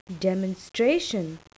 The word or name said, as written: demonstration